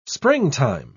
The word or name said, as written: springtime